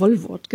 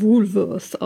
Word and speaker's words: Woolworth: weil ich als Kind immer Wollwort gesagt habe. Das hatte ich nämlich von meiner Oma so gelernt. Und ich musste erst 20 oder so werden, bis mir jemand erklärt hat, dass es eigentlich eher Woolworth ausgesprochen würde.